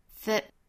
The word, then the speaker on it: that: Never convince yourself that. 后面这个 that 需要做弱读哈，我们读成 the, 非常短非常快的一带而过。后面这个 t 省掉 that what other people are telling you to do.